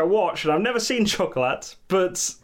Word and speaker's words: Chocolat: Don't pronounce the T. – Chocolat. – Chocolat. – Chocolat. – Chocolat. He's given us a scene from Chocolat. – He's given us a scene from Chocolat because that's how it's spelt. Like, the French must just see T's and go, nah.